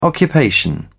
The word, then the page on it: occupation: British English